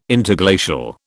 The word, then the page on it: Interglacial: British English